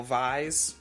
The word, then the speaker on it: Kvies: I think the K is silent. Kyle Vies. – All right. Kyle Vies. K-V-I-E-S.